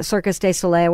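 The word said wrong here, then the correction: Cirque du Soleil: or whatever. – Circus Soleil. – Soleil. – Yeah. – And Cirque de Soleil.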